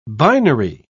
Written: binary

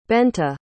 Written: Bente

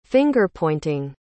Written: finger-pointing

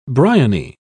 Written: bryony

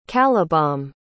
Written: calibom

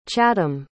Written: chatham

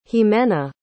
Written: ximena

